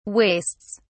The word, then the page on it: wastes: British English